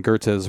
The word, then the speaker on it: Goethe: Is it Goethe? – Goethe, yeah.